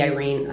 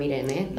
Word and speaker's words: Irene: Because my name is Spanish, and I didn't want, my name can be pronounced in English or in Spanish, but I don't want to be Irene. I am Irene. Like, that's my grandmother's name.